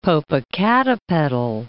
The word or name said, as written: Popocatepetl